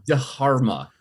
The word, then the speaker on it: Dharma: Drama? Something or other? – I think it's a Dharma. – Dharma? – It's pronounced Dharma.